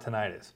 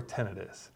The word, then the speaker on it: tinnitus: Today we're going to talk about tinnitus, or tinnitus, depending on where you want to put the emphasis on that syllable.